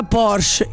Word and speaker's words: Porsche: Il nome corretto è quello della Porsche e si pronuncia in due sillabe. Porsche. – Sce. – Sce. Quella E finale non è muta. – No. – Si sente come... e come come un piccolo respiro finale.